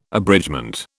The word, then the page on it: abridgement: British English